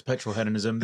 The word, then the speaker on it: Petrolheadonism: which was Petrolheadonism. And I have said that right, haven't I? – Yeah, it's Petrolheadonism.